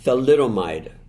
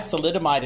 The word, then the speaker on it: thalidomide: Thalidomide. – Thalidomide.